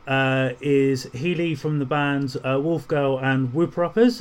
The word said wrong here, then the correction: Whooper Ups: Have I said that right? – The Whooper Ups. – Whooper Ups. Sorry, I knew I was going to get it wrong. – So close.